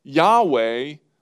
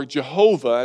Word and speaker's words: Yahweh: Yahweh, or Jehovah, as it's sometimes pronounced.